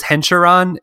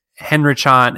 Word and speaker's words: Henrichon: Written by Robbie Thompson, illustrated by Nico Henrichon. I thought it was Henricharon. It's Henrichon. Henrichu.